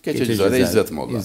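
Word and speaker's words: Keçecizade İzzet Molla: İsmi, Allah doğru telaffuz ettik değil mi hocam? Geçecizade İzzet Molla. – Evet. Geçecizade İzzet Molla.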